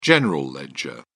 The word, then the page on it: general ledger: British English